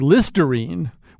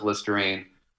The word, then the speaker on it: Listerine: Yeah, he said he's been a little skeptical about vaccines, but like Listerine, which rhymes with vaccine, he thinks is pretty good.